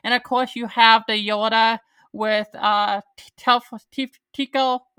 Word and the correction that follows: Tycho: I can't pronounce his name, Captain Tycho, that mind control and stuff like that. So, oh, Captain Tycho, yeah.